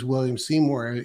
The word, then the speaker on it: William Seymour: It wasn't that he, he tried one, two, and three first and and decided to then then use William Seymour. Am I saying that right? – It's exactly right, and that's throughout the scripture.